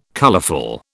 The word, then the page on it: colourful: British English